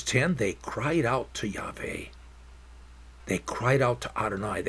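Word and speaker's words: Yahweh (יהוה): Yud-Heh-Vav-Heh, that I pronounce Yahweh.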